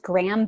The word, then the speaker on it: Graeme: His name is Graham Bass, and it's spelled G-R-A-E-M-E.